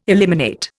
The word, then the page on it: eliminate: Indian